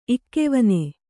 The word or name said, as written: ಇಕ್ಕೆವನೆ